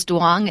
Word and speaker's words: duong: The word is duong. It's spelled D-U-O-N-G.